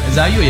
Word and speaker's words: Zayu: Namanya Maple, Zafu sama Clutch. – Zayu apa Zafu nih? – Zayu ya. – Oh, Zayu. – Zayu ya bacanya ini.